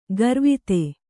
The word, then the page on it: ಗರ್ವಿತೆ: garvite